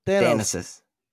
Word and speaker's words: Thanasis: Thanis, I think. I have no idea how to pronounce it. Thanais. How do you pronounce that? – T-H what? – Thanaisis. – Thanasis.